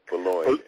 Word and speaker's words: Bologne: Joseph, is it Bologna? Joseph, no, no. yeah, you, you did butcher it, brother. It's Joseph sorry. Bologna. Joseph Boulogne.